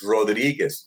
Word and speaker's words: Rodriguez: It's Rodriguez. No, it's Rodriguez. Roll your R's. There's a Z at the end.